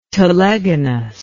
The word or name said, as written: telegonus